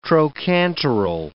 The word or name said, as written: trochanteral